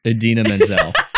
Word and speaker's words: Idina Menzel: I picked, I hope I don't John Travolta this, Idina Menzel.